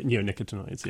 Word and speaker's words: neonicotinoids: Neonicotinoids. – Neonicotinoids. – I think is the name. Yes. – Yeah. There was a recent study published. Yeah. Oh, yeah. Neonicotinoids.